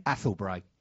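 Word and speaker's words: Athelbrae: Athel Bray. Athel Bray Limited. How do you spell it, Joe? – It's A-T-H-E-L-B-R-A-E, isn't it? – Yeah.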